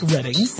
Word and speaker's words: Reading: And yes, I know it's pronounced Reading.